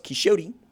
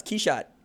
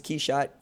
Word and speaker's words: Quichotte: And because I was saying, like everybody else, Quichotte, right? And it's like, no, it's Quichotte. And then if you go to the wiki page, you can see, like, the pronunciation right there is Quichotte, French.